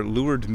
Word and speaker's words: leeward: Now if you see this word written down, it looks like leeward. L-E-E-W-A-R-D. Meaning downwind. That is actually pronounced leeward. Kind of like that hot chick at the bar lured me into her. That's lured. Lured is away from the wind.